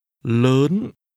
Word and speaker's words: lớn: Lớn. Lớn. – Lớn. This word is pronounced with a rising tone.